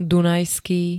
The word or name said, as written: dunajský